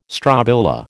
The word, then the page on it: strobila: American English